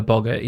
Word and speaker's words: Boggart: Boggart? – Her Boggart, yeah.